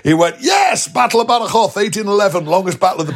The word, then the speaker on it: Badajoz: spelt Badajoz, but it's pronounced Badajoz.